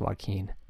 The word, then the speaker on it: Joakim: But I do think even Wilbon will be surprised to know that Joakim Noah's name is pronounced Joaquin.